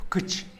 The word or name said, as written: pkïtx